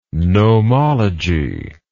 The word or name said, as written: gnomologist